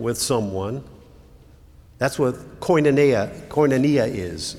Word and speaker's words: koinonia: There's a Greek word spelled with a K, koinonia.